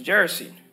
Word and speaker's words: Gerasene: This is Gerasene.